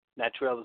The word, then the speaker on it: Natrell: Natrell is spelled N-A-T-R-E-L-L.